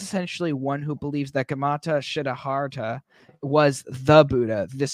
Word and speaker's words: Gautama: So, do you know how to pronounce his like proper first name? – Let me read it and give it a try. – Gautama the Buddha, 563 to 483 BC. – Gautama. – Gautama. Gautama. Okay, I'll trust you.